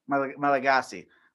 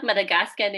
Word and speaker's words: Malagasy: Malagasy. – Yes. – Thanks, Google. You failed me. – Usually people say Madagascan. It's not Madagascan. It's Malagasy. And people are called Malagasy as well. Language is Malagasy.